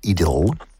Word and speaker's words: idyll: idyll, or if you prefer the English pronunciation, an idyll.